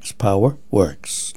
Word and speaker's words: PowerWerx: It's spelled P-O-W-E-R-W-E-R-X. Power Works now offers permanently bonded red slant black Anderson power pole sets.